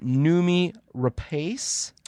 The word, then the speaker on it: Noomi Rapace: Now, I'm going to butcher this last name here, but I believe you pronounce it Noomi Rapace. – Sounds right. Works for me.